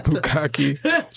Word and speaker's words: bukkake: You did fucking bukkake.